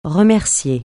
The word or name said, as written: remercier